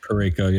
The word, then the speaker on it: Parayko: They had Justin Falk, Colton Parayko. I think I'm saying his name right. I always forget how to say that name. – Parayko, yep.